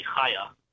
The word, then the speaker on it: Chaya: It's Hebrew. It's Hebrew with a chet, but in English we write with a CH usually, Chaya.